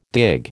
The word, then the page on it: dig: American English